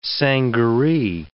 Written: sangaree

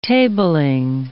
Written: tabling

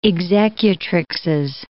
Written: executrixes

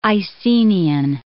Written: Icenian

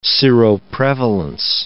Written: seroprevalence

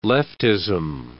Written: leftism

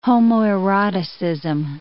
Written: homoeroticism

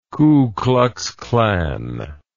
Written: ku klux klan

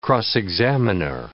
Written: cross-examiner

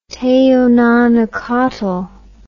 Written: teonanacatl

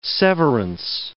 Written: severance

